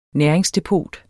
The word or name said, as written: næringsdepot